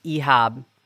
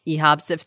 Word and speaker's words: Ihab: Because his name is Ihab Maslamani. – Yes. – It's a Libyan name, mm-hmm, and it's spelled I-H-A-B. – So yeah, it's just, it's like an Americanization. – That sounds like IHOP.